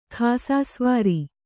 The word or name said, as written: ಖಾಸಸವಾರಿ